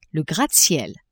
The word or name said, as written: gratte-ciel